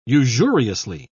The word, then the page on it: usuriously: British English